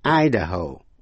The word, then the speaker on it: Idaho: Tiểu bang Ohio, nhấn mạnh vào vần nhì, nhưng Idaho, I-D-A-H-O, nhấn mạnh vào vần đầu, Ohio, Ohio, Idaho. Colorado, C-O-L-O-R-A-D-O, Colorado.